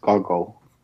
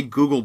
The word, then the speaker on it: Goggle: I think it's pronounced Goggle. Yeah, because it's goggling at someone. – Right, okay. I never remember... Yeah. I always want to say Google Box, and I know it's wrong.